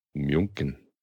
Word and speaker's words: München: Polaner from München. – Oh, okay. Well, yeah. – Actual German brewer. – Actual European brewer. Okay. – München, which is the proper way to say Munich.